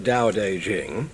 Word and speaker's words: Tao Te Ching: Well, we get the name from primarily, and the information or understanding of it primarily from a book called the Tao Te Ching, T-A-O-T-E-C-H-I-N-G, pronounced Tao Te Ching.